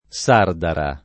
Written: Sardara

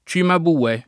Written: Cimabue

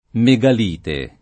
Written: megalite

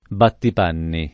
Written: battipanni

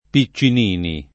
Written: Piccinini

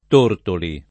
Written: Tortoli

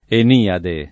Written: Eniade

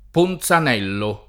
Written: Ponzanello

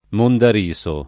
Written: mondariso

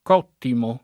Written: cottimo

